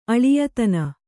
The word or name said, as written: ಅಳಿಯತನ